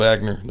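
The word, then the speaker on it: Aigner: It is pronounced Agner. Don't even say it's not.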